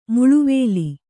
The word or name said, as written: ಮಳೆವಿಲ್ಲು